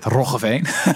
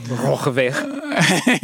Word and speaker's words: Roggeveen: You probably can't pronounce that, Roggeveen. – Roggeveen. – Yeah, there you go.